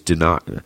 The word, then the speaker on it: Dinocrates: Dinocrates. That's it. That's how to say it. Dinocrates. Emmanuel's right.